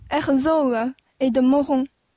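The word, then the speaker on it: Herzog & de Meuron: Herzog et de Mohon. Mais c'est la prononciation Oh. – en français.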